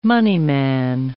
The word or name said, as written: moneyman